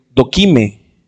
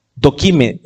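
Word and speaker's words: doquime (δοκιμή): Eh, estaba eh, estudiando el, pasaje bíblico en, el diccionario, en el original griego, y prueba se escribe y se dice doquime. dokime, D-O-K-I-M-E, e, Doquime.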